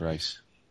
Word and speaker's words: Reis: I got Cowl by Kyle Higgins, Alex Siegel, and Rod, is it Heiss? Just like the DC dude? Because it's spelled the same way, R-E-I-S. So I think in this case it's Rod Rice.